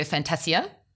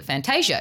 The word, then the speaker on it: Fantasia: So he pronounces it Fantasia because I would read that as Fantasia. – Like Anastasia, the cartoon. – Yeah, or Fantasia, the movie.